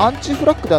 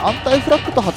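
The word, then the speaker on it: Anti-Flag: 実際はアンチフラッグではなくアンタイフラッグと発音するそうですね。アンチって書くんですけどね。バンド名からしてね、なんか反アメリカ的だとか誤解されてるらしいんだけど、実際はこのアンタイフラッグっていう言葉は、反戦団結の意味を持つバンド名だそうです。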